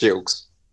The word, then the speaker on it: Gilkes: It's Gilkes.